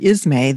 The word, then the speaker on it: Ismay: And I couldn't help but notice that the word Ismay, the name of your town, rhymes with dismay, you know, dismay.